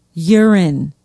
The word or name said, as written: Euren